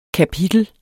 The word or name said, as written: kapitel